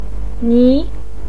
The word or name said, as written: ni2